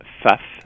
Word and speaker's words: Pfaff: Par la maison, je crois qu'on prononce Pfaff. Alors, c'est P-F-A-F-F. – D'accord. – P-F-A-F-F.